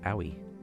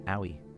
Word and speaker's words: Ooi: And uh, before that, from Tokyo, interesting, was o- Owie, spelled O O I, but pronounced Owie.